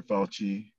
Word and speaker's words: Fauci: I believe in listening to our leading epidemiologist or, you know, Dr. Fauci. You know, like, oh, wait, did I say that right? – Yeah.